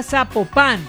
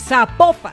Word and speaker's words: Zapopan: Oiga, nos escriben, nos escriben los oyentes y que no se, no se pronuncia Zapopan, sino que se pronuncia Zapopan.